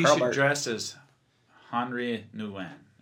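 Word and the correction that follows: Henri Nouwen: Is that how you say it? – I think it's just Henry Nouwen, but maybe.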